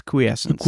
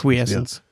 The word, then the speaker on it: quiescence: We Googled it and we believe it is quiescence. – Quiescence. – Or quiescence, I guess, if you're American.